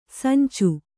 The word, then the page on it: ಸಂಚು: sancu